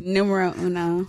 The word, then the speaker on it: numero uno: And I'm joined by the Nuambre. Was it Nuambro? What was it? – Numero uno. – There we go. I've been saying it wrong for the last 2 weeks.